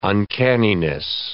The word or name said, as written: uncanniness